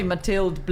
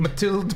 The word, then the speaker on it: Mathilde: It's probably Mathilde Blind. – Mathilde Blind. Yeah. And so Mathilde Blind wrote a biography of her, and she went round. – She's South African. – Mathilde Blind.